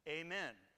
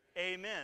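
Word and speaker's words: Amen (אמן): It's pronounced Amen. Like it's amazing, isn't it? Let's just try it in the Hebrew. Ready? Amen. It's amazing.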